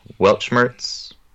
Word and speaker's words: Weltschmerz: Weltschmerz, I don't know if I'm saying that right, probably not. I don't say anything right, but you said it right. – It was fantastic. – This checks out. You said it correctly.